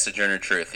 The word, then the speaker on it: Sojourner Truth: Yes, Sojourner Truth.